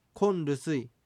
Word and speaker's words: コロルスイ: それにしたいという意味のルスイがついて、コロルスイ、何々を持ちたい、つまり何々が欲しいという意味になります。なお発音はコンルスイとなります。